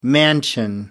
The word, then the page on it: mansion: American English